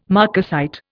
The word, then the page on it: marcasite: Indian